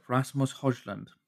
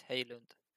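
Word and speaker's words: Højlund: Rasmus Hojlund. Did I say that right? – Yeah, you did. In Danish, it's Hojlund.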